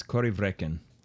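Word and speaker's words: Corryvreckan: And really, as Gaelic pronunciations and spellings go, that one is fairly straightforward. – Mm-hmm. – But it's Corrievreken.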